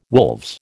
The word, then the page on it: wolves: American English